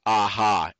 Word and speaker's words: AHA: It's pronounced AHA, A-H-A.